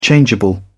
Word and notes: changeable: British English

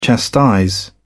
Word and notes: chastise: British English

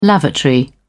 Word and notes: lavatory: British English

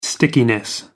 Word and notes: stickiness: American English

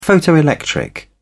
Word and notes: photoelectric: British English

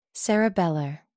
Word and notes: cerebellar: American English

